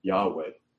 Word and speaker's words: Yahweh: And we would pronounce it Yahweh.